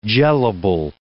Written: gelable

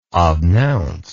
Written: obnounce

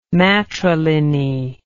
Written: matriliny

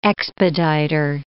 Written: expeditor

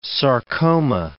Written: sarcoma

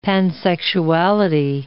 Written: pansexuality

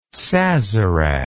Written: sazerac

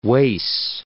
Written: Wace

